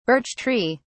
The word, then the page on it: birch tree: British English